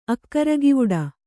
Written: ಅಕ್ಕರಗಿವುಡ

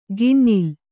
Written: ಗಿಣ್ಣಿಲ್

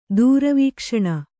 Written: ದೂರವೀಕ್ಷಣ

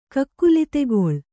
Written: ಕಕ್ಕುಲಿತೆಗೊಳ್

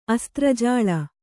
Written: ಅಸ್ತ್ರಜಾಳ

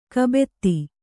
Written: ಕೈಬೆತ್ತ

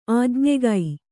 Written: ಆಜ್ಞೆಗೈ

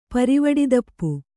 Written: ಪರಿವಡಿದಪ್ಪು

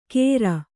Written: ಕೇರ್

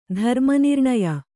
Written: ಧರ್ಮನಿರ್ಣಯ

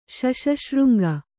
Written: ಶಶಶೃಂಗ

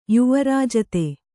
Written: ಯುವರಾಜತೆ

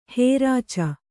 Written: ಹೊರಚ್ಚು

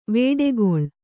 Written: ವೇಡೆಗೊಳ್

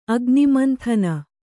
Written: ಅಗ್ನಿಮಂಥನ

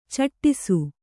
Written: ಚಟ್ಟಿಸು

